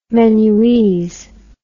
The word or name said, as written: menuese